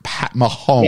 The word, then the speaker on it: Mahomes: Mahomes. Am I saying that right? Pat, Pat Mahomes. – Yes.